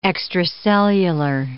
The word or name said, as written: extracellular